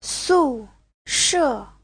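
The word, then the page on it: sushe: su4she4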